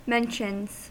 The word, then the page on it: mentions: American English